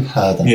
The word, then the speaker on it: purdah: How do you spell that? – In, and then P-U-R-D-A-H. – Perda. I've never heard of that word. – Oh, it's a Hindi term, and it basically means, like, in isolation, keeping shut up.